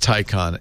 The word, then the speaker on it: Taycan: T-A-Y-C-A-N, but Sam Abulsamid says you pronounce it, Porsche wants you to pronounce it Taycan.